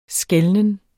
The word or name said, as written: skelnen